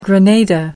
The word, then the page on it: grenada: American English